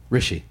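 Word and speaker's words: Hrishi: Rusha, is that right? Rusha. – No. – How do you say it? – Rishi. – Rishi.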